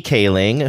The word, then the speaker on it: Kaling: starring Mindy Kaling, who I think is a great. Wait, did I say her name wrong? – No.